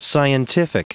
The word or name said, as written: scientific